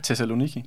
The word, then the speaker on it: Thessaloniki: marts 1913, der går han en tur så rundt i Thessaloniki gader. Hvad, hvad, hvad, du udtaler det? Thessaloniki. Ja, Tak skal du have.